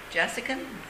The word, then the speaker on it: Jasikan: Jassikan, J-A-S-I-K-A-N.